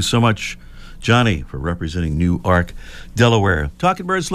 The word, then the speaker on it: Newark: These pronunciations, you've got to be looking up stuff here because it's not pronounced like Newark, New Jersey, they tell me. It's Newark, Delaware.